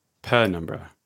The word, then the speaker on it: penumbra: Penumbra. – Penumbra? – Yeah, Penumbra, because it's PN, not PN.